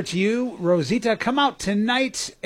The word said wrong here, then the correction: Rosalita: Rosalita, I'm sorry. I took away a syllable from her. That was uncool of me.